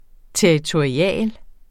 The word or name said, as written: territorial